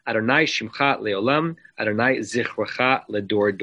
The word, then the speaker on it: Adonai: From here, we get the notion of uh, looking at the letters Yud, Hey, Vav, Hey, and pronouncing as Adonai.